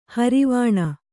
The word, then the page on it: ಹಾರಿವಾಣ: hārivāṇa